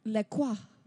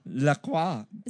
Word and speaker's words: La Croix: It's all about La Croix. – La Croix. – Is that how you say it? I think it's La Croix, actually. – La Croix.